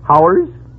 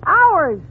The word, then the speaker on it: hours: Now, how do you pronounce H-O-R-S-E? – H-O-R? Oh, that's easy. That's horse. – Fine. Now, how do you pronounce H-O-U-R-S? – H-O-U-R-S? Howers? – You pronounce it ours.